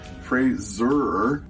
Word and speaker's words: Fraser: That's Fraser, sounds like razor, which has been mispronounced all my career.